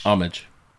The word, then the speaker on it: homage: Homage. – So the H is silent, but the rest of it is pronounced homage.